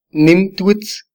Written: nim twitz

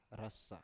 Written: rasa

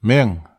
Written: még

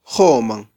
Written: joman

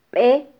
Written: be

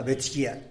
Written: betxgia

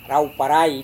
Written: rau-parai

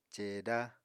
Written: ceeda

